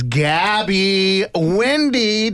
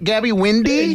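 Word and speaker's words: Gabby Windey: The fact that right now on the phone with us is Gabby Windy. Did I say that right? Gabby Windy? – Yes.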